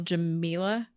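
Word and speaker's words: Jameela: Yeah, because I'm pretty sure that her name is pronounced Jamila.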